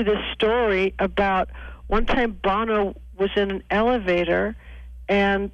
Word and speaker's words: Bono: you pronounce it Bono, and it was Sonny.